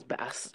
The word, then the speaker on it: bass: it literally says bass, it's pronounced bass,